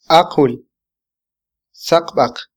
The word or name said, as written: aqwil - saqbaq